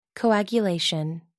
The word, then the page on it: coagulation: American English